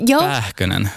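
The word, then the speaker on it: Pääkkönen: Uttalar jag namnet rätt? – Pähkönen.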